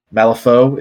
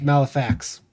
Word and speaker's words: Malifaux: Malifaux is the pronunciation. Malifaux. Oh, it's French. F-A-U-X. – Malifaux. Oh, I've seen it and always pronounced Malifax, because I'm...